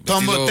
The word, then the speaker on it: Tomboy: Tomboy, T-O-M, sí, ajá, es Tomboy.